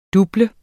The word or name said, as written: DOBL